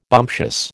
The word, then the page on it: bumptious: American English